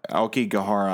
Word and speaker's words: Aokigahara: Aokigahara.